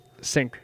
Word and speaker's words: Cink: Mis- so, Mr. S- how do you say his last name? – Cink.